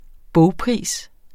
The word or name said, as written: bogpris